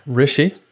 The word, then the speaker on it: Rishi: I'm your host, along with my special guest host, Rishi. Welcome to the show. Hi. Am I saying that right, by the way, Rishi? Yeah, that's that's correct.